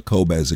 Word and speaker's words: Qobuz: It's Q-O-B-U-Z, pronounced Qobuz, and they are the most artist-friendly of all digital providers.